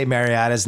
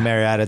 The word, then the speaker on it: Mariota: I knew it heading in, and I can't say Mariota's name correctly. I was calling Mariota. It's Mariota. Yeah. I just say it wrong every time.